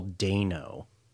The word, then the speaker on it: Dano: Also found out today that it's pronounced Paul Dano.